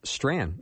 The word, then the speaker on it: Strachan: And the way you spell his last name is S-T-R-A-C-H-A-N, although it's pronounced Strand.